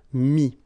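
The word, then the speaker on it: mis: And then mettre, to put, so E-T-T-R-E will give you this I-S, mi. Same thing here, final S is not pronounced, mi, okay? And then prendre, so E-N-D-R-E will give I-S, pris, okay?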